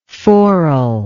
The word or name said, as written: forel